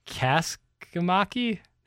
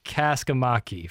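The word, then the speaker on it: Kaskimäki: Aleksanteri Kosmaki? – So the first K is pronounced, but the second K is silent. – Kaskamaki? – Nice. Confident. – Aleksanteri Kaskamaki from Finland.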